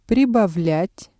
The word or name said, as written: прибавлять